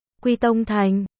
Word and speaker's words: Quy Tông thành: Tất cả mọi người đều nói ba chữ đó đọc là Quy Tông thành. Diệp Tố sau khi học qua cổ văn trong luân chuyển tháp nhìn qua cũng thấy xác thật không sai.